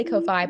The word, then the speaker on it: Ko-fi: Okay, I say Ko-fi, but it. – apparently it's Ko-fi because it's supposed to be coffee.